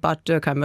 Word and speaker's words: Bad Dürkheim: Born in Bad Dürkheim, and Trump actually, I thought the pronunciation was quite good. He said Bad Dürkheim, but maybe we can listen to that.